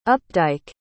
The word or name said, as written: updike